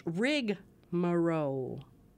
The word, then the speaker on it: rigmarole: But it has no first A. It's rigmarole. – Rigmarole? – Rigmarole. – Well, it's not as fun to say if it's rigmarole. – No, it's not. And really? – Even though it's spelled that way, one of the preferred pronunciations is rigmarole.